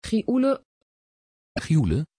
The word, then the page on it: Gioele: Dutch